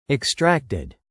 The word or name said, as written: extracted